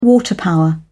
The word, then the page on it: water power: British English